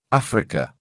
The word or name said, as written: Africa